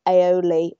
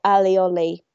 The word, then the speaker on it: aioli: I love aioli or alioli, depends how you say it, when I'm on holiday.